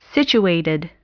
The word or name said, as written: situated